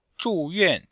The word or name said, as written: zhu4yuan4